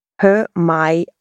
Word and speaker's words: Hermione: Im 4. Band schmuggelt die Autorin ein, wie man Hermione richtig aussprechen soll. Hermione.